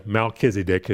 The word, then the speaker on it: Melchizedek: Now, Baker and Carpenter, transliterate Melchizedek into English a little bit differently than we're used to spelling it in most Bible translations, but it's still kind of pronounced the same. They transliterate it in their dictionary as M-A-L-K-I-Y-S-E-D-E-Q. Melchizedek, as you can see how it would be pronounced.